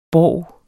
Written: borg

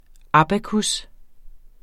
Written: abacus

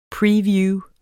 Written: previews

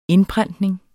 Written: indprentning

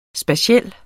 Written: spatiel

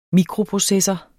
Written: mikroprocessor

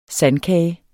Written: sandkage

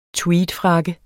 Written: tweedfrakke

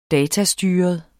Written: datastyret

